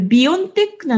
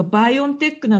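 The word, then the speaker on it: BioNTech: 世界のマスコミが、これビオンテックなのかバイオンテックなのか、どうやって発音したらいいのかと、世界のマスコミが困って、バイオンテックのドイツの社長に、お宅の会社の本当の発音はって聞いたら、あ、どっちでもいいですとか言われて、さらに困っている。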